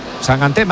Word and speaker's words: Sangante: No, que sangante, se pone sangante. – Yo pronuncio como me digáis. – Bueno, eso lo he leído yo, que dice que es sangante. – De hecho, me ha dicho, tú acuérdate de mangante.